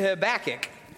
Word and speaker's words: Habakkuk: Turn with me to Habakkuk.